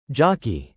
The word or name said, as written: jockey